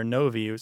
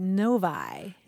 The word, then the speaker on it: Novi: We heard from Frank Maynard, who's in Northern California, and the city that he lives in is spelled N-O-V-I. Any thoughts about how... – I don't know. Navi or Novi seemed like the logical ones, but what is it? – Novi. – Novi. – Isn't that weird? – Maybe it's like Lodi, L-O-D-I.